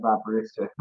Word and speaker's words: Aberystwyth: Um, and you did very well with the pronunciation of Aberystwyth. a lot of people don't.